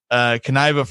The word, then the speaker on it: Kniva: So it is Kniva, right? That I heard him say it like five times today. I'm pretty sure that sounds right. About right.